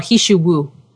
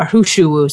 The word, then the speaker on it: He Shou Wu: which is an herb called Hishu Wu. Or Hushuwu, some people say. H-E-S-H-O-U-W-U is the way to spell it.